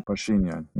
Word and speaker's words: Pashinyan: So, what do you think happens to the Armenian Prime Minister, if I pronounce his name correctly, Nikol Pashinyan? – Pashinyan, mm-hmm.